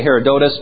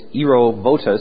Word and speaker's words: Herodotus: How about the work of, we would normally say Herodotus, but correctly it's Herovotus, that's how his name's pronounced.